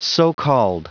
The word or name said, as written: so-called